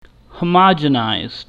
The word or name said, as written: homogenized